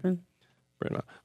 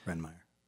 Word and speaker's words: Bryn Mawr: Yeah, that's how you say it. Bryn Mawr. Okay. – Bryn Mawr.